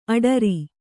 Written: ಅಠಾರಾ

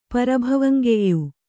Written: ಪರಭವಂಗೆಯ್ಯು

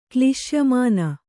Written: ಕ್ಲಿಶ್ಯಮಾನ

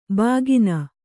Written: ಬಕ್ಕನೆ

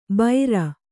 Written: ಬೈರ

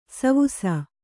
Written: ಸೇವಿಸು

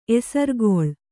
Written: ಎಸರ್ಗೊಳ್

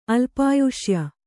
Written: ಅಲ್ಪಾಯುಷ್ಯ

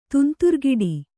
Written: ತುಂತುರ್ಗಿಡಿ

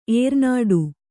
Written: ಏರ್ನಾಡು

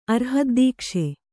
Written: ಅರ್ಹದ್ದೀಕ್ಷೆ

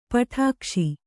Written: ಪಿಡಿಕೈಸು

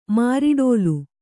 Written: ಮಾರಿಡೋಲು

